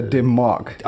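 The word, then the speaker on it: Dim Mak: So with labels such as Sony / Columbia, DIM, Mac. Is that right? – No, it's actually pronounced a dim mark.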